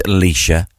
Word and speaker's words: Laoise: Like there's one in Ireland, Alicia, but you spell that L-A-O-I-S-E.